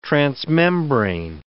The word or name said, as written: transmembrane